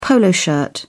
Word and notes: polo shirt: British English

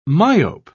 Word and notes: myope: British English

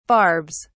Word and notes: barbs: British English

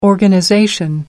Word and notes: organization: American English

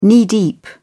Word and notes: knee-deep: British English

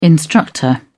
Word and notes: instructor: British English

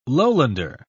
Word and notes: lowlander: British English

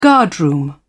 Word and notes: guardroom: British English

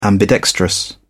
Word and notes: ambidextrous: British English

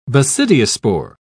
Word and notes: basidiospore: British English

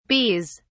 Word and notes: bees: British English

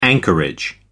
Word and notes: anchorage: American English

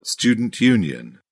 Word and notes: student union: British English

